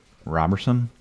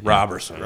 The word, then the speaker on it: Roberson: Well, the other breakout star has been Andre Roberson. That's how you say it. – Roberson.